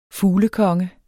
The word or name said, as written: FUGLEKONGEN